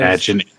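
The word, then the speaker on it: Mädchen: Laura Flynn Boyle, and Madchen, if that's how you say her name. – It is. – Madchen on the cover, and it is like a ludicrously yummy cover.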